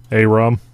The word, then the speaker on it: Arum: It's A-R-U-M. That's why I spell it out all the time. – Arum. – Yeah. Mm-hmm. Arum. Most people spell it A-R-E-M or A-R-R-E-M.